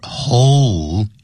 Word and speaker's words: hole: And you can see the title over here that goes, Holes on the Moon.You should pay very close attention to the pronunciation of this word, hole, H-O-L-E. Because this word involves this letter L, so that's why as we read this word, we should say hole.